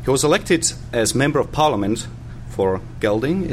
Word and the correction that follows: Gedling: is it? That's how you pronounce it? – Gedling, in 1987 and re-elected in 1992.